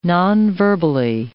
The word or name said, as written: nonverbally